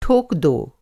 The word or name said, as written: ठोकना